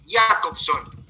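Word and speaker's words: Jakobson: Eh, ah pero este se pronuncia, la J se pronuncia como una I, Jacobson.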